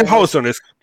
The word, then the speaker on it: Hewson: Paul Hewson. – Ahorita Hewson. Ese vamos Hewson. A hablar de... Sí, se pronuncia Hewson, disculpe.